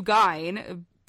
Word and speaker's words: Gein: He also inspired a slew of very dark songs, such as Dead Skin Mask by Slayer, Nothing to Gein, spelled like Ed Gein, so like Nothing to Gain, witty, by Mudvayne, and the aptly titled Ed Gein from the Ziggins.